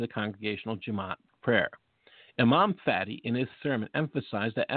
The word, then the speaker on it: Fatty: Yes, that is correct. I said Fati. His last name is spelled F-A-T-T-Y.